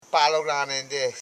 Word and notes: palolaren: pa.lo.la.ren; Koro

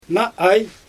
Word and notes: naa aj: Xyzyl

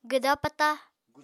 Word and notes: gwda-pata: apatani

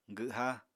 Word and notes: gwha: apatani

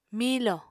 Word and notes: miilò: Apatani

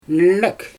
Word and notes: nök: Xyzyl

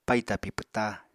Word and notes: paì tapi pwta: Apatani

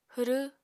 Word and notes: hwrw: apatani

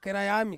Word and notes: keraiami: Ansus

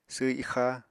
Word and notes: sww ixa: apatani

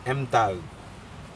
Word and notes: emtac: Aneityum

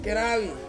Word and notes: keravi: Ansus

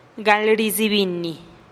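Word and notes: gal rizibinih: Teotitlán del Valle Zapotec

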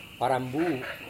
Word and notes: warambu: Serewen

